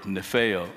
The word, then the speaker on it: nepho: The word, Greek word, nepho, is the way it's pronounced.